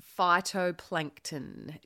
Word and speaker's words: phytoplankton: Now, tell us about phytoplankton. First of all, did I pronounce it correctly? – You did. Points for that.